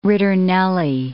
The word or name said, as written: ritornelli